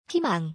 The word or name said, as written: 희망